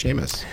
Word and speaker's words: Seamus: Besides, his middle name is Seamus. – Oh, that's a good name. – Peter Seamus O'Toole. – I guess he is Irish, so it would actually be pronounced Seamus.